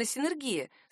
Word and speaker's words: синергия: Правильно, действительно, синергия. Словари не допускают других вариантов. Стоит напомнить, что синергия — это слово произносится твердо через «не».